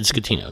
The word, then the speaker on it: Scatino: Yes, you did, Scatino. That's right. – Right. Like the way I remember, it's like school. – Yeah, school. School. That's the way I always advise people to pronounce it.